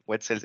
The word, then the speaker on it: Wetzel: Wetzel se escribe W e-T-Z-E-L, apellido alemán.